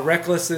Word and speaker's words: Reklis: Rek-lis is simply the phonetic spelling of the word Rek-lis. R-E-K-L-I-S is the way we spell it.